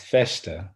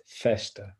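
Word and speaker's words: Vechta: And it's Feshta. So the V sounds more like an F. It's like a soft F. It's Feshta. Rasta Feshta.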